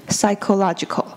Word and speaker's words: psychological: 这个字呢，它看起来很难，看起来有点可怕，但是它的发音其实很简单，因为 ps 呢，我们不是念 ps，我们是念跟 s 一样的 s。那在这里呢，y 发长音念 i，所以是 psy 念作 psy。那还有 cho 这边的 ch 呢，它不念作 ch，它念作 k，所以 psycho 念作 psycho，那再加上 logical 就会变成 psychological。